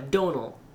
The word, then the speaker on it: Domhnall: See, I knew I would mispronounce that. – Yeah, Donal. Yes. Like Donal Logue. – Yeah, just like it's spelled the actual Irish word Donal Logue, like phoneticized it.